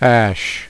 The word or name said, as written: ash